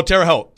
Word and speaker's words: Terre Haute: Is it Terre Haute, Indiana? I don't know how to say this. – Oh, Terre Haute. – Terre Haute, yeah, Indiana.